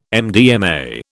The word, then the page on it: MDMA: British English